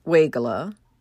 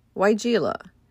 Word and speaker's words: Weigela: Some pronounce it Weigela, others say Weigela.